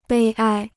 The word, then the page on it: 悲哀: bēi āi